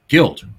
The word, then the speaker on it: Guild: So anyway, Tommy comes in and his girlfriend, who is played by, now her last name is spelled G-U-I-L-D. How would you pronounce that? – Guild. – Guild, right? – Yeah. – It's pronounced Guild. – Of course it is. – Yeah. When she signed when she signed with 20th Century Fox early on, they tried to do a campaign saying Guild rhymes with wild.